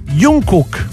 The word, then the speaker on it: Jungkook: Sì, esatto, allora Jungkook.